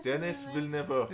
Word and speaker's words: Denis Villeneuve: How do you say Denis Villeneuve.